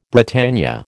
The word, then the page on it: Britannia: American English